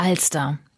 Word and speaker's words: Alster: Da haben wir ja schon eine Menge gelernt heute, aber eins haben wir noch auf dem Zettel, die Alster. Die Alster in Hamburg, hier wo ich sitze. Wie ist die denn zu ihrem Namen gekommen? Die betonen wir ja schon mal auf der ersten Silbe, also vielleicht ist sie dann nicht aus dem Slawischen.